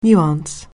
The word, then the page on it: nuance: anglais